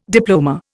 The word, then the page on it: diploma: Indian